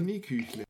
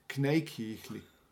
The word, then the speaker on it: Kneiküchle: Und Knieküchle. Knieküchle, okay. Also auf Schwäbisch heißt Knie, Knei, Kneiküchle.